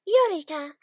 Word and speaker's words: Eureeka: No, it's Eureka. But hey, your way works too.